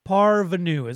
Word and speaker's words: parvenu: Parvenu is what it's pronounced. It's a noun. – Parvenu. And it sounds French. – It is French.